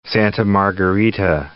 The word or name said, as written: Rancho Santa Margarita